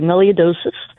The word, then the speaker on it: melioidosis: Tell me about melioidosis. Is that how you say it? What is this? – You got it. You pronounced it correctly, melioidosis.